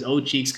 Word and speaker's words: Owcheeks: O-W-C-H-E-E-K-S. So I pronounce it as O-Cheeks because that's how people do it in the Philippines.